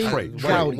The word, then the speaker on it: Gowdy: and so Ted, Ted uh, Gowdy or Groudy? Trey Gowdy. Trey, You're saying it right. Trey Gowdy.